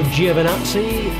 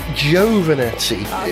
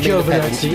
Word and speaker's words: Giovinazzi: 14th, Antonio Giovinazzi in the Alfa Romeo. – Actually, I think it's pronounced Giovinazzi, if I'm Giovinazzi, okay.